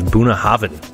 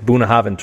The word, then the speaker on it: Bunnahabhain: Moving on from Brookladdie, we're going to go to our only other Islay distillery that does not peat, and that is Boonahaven. – Oh, can you spell that? Because it sounds like there's a V in there. – It does sound like there's a V in there. It's actually spelled B-U-N-N-A-H-A-B-H-A-I-N.